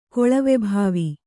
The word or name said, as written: ಕೊಳವೆಭಾವಿ